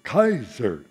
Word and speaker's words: Kaiser: which, of course, in the New World, we take in a diphthong, the first of the two letters, K-A-I, and we pronounce A. But I learned my name from the German side, which was continental, so I say Kaiser.